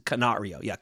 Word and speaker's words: Canario: And I'm going to make sure Canario. I pronounced it correctly.